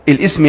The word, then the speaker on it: الاسم: والاسم، الاسم يعني، بس طبعا الاسم لا تنطق الهمزة فيها.